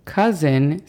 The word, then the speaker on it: cousin: La pronunciación fonética de esta es A-N-T. Aunt. A continuación tenemos cousin. Cousin significa primo o prima. La pronunciación fonética de esta es C-A-S-E-N.